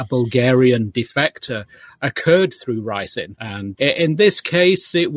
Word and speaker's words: ricin: We had somebody who was making ricin. I believe that's how it's pronounced in, in one of the ricin. Yeah, ricin is in place in the book.